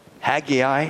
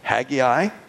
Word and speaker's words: Haggai: And so the prophet of Haggai, actually I've heard three different ways how to pronounce that. I've heard Haggai, which is probably the most common. Haggai, our professor back at what is now Davis College, then Practical Bible Training School, said Haggai, whatever.